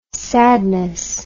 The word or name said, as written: sadness